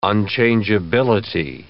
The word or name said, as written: unchangeability